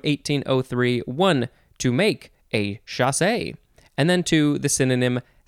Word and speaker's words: chassé: Next we have chasse, C-H-A-S-S-E, with an accent on the E. So you have to say chasse.